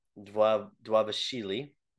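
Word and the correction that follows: Dvalishvili: Dwalishwili. The V's are pronounced Dwa, like W's.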